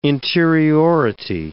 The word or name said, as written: interiority